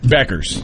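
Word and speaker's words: Bueckers: Beckers. – Beckers. – Yeah. – Bueller. – Rob called her Paige Bueller. – Buchers. – That's what I thought it was. – And it's Beckers, but she's okay.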